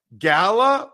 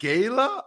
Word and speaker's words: gala: Saturday, I went to a gala, or is it gala? I don't even know what they're called. G-A-L-A. I think either pronunciation is sufficient.